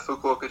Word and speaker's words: Fukuoka: He's a former international student, went on a trip to Fukuoka, Japan. Is that, that's how you pronounce it? – Yeah, Fukuoka, Japan. I'm glad that you pronounced it correctly.